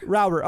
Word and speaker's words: Rauber: I'm always worried I get the pronunciation wrong. – Rauber. – Okay. I got it right then. All right. – You did. – I was, for some reason, thought it was like a rower, like row your boat. I don't know why. But no, that'd be R-O-W.